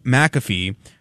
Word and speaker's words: McAfee: But joining us right now is Sean McAfee, or I think that's how you pronounce it.